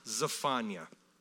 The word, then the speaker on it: Zephaniah: But it's actually pronounced Zephaniah.